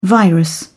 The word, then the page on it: virus: British English